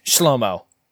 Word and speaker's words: Zschlomo: Zeke, Zachary, and of course, Shlomo. Spelled Z-S-C-H-L-O-M-O. The Z is silent.